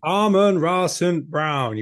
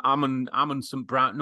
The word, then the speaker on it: Amon-Ra St. Brown: I always want to call him Amon St. Brown. No, Amon-Ra.